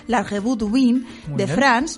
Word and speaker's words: La Revue du Vin de France: Según publica La Vanguardia, una de las revistas de mayor prestigio del mundo del vino, la francesa La Revue du Vin de France, dedica un dossier especial, esto sí que lo he dicho bien, Robert, sí.